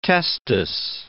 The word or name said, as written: testis